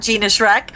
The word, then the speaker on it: Gina Schreck: Well, probably the best way is on Twitter at Gina Schreck.